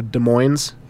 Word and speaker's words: Des Moines: Des Moines is spelled D-E-S-M-O-I-N-E-S. So people say Des Moines or Des Moines. It's Des Moines.